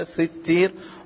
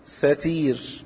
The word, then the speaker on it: ستير: وللستير روايتان، إحداهما كسر السين ستير وتشديد التاء كما هي تنطق كذا ستير، والأخرى بفتح السين وكسر التاء ستير. إن الله حيي ستير، فتح السين وكسر التاء المخففة فيها، هكذا تنطق هكذا، ولكن الأولى أشهر.